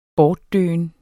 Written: bortdøen

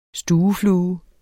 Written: Stueflue

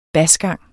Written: basgang

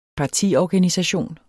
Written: partiorganisation

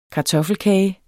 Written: kartoffelkage

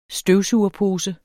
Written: støvsugerpose

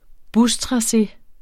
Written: bustrace

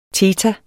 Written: theta

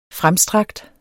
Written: fremstrakt

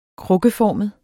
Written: krukkeformet

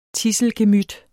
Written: tidselgemyt

